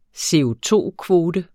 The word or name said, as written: CO₂-kvote